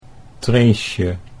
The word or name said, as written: Treesje